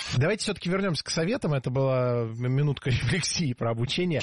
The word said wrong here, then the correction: рефлексия: Рефлексия. Неверно. В этом слове ударение должно падать на второй слог. Рефлексия. Для запоминания предлагаю однокоренное существительное с непроблемным ударением. Рефлекс. Рефлекс. Рефлексия. Запомнить легко, и пора бы уже это сделать.